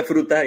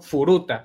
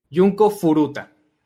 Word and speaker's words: Furuta: Pues sí, pero sí pronunciándolo de manera fuerte, ¿no? Furuta, para que se entienda que es el apellido. Fruta, es con U. Es, F-U-R-U, no F-R-U. Yunko Furuta.